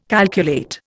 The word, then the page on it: calculate: Indian